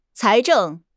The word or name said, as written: 财政